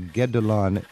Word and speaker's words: Guédelon: So, Daniel, this is called the Château de Guédelon. I think it's spelled G-U-E-D-E-L-O-N.